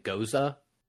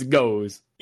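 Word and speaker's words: Gose: Now, okay, some people pronounce it goza as well. So if we're technically saying it improperly, then fuck you. – We're in America. We can say whatever we want. It's goes.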